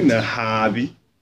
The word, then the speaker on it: Nahabi: And today we have a very important guest, a lady in our midst, Madam Sarah Nabahi. I pronounce it well? – I can pronounce it. – Nahabi. Oh, sorry, I said it the other way around. Do you know how to pronounce it? – Sarah Nahabi. – Hi, that's correct.